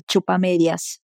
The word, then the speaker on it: chupamedias: La CH se pronuncia igual que la palabra chao en portugués. Ahora vamos a repetir la frase completa. Ahí viene el chupamedias.